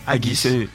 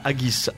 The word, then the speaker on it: haggis: Haggis. – Il y a Gilles qui apparemment. – Ouais. J'avais l'habitude de le prononcer haggis. Ouais. Et on m'a dit non, c'est haggis.